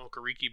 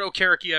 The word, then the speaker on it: Okereke: Bobby Okereke, I've called him Okereke before. But Okereke, I believe, is the proper pronunciation.